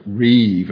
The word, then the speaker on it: rib: The Hebrew word is that it is a reeve, R-I-B in English, but pronounced reeve, a particular form actually known in the ancient Near East pursuing a suit in a court of law, effectively.